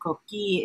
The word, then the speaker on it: coqui: And cookie is spelled C-O-Q-U-I.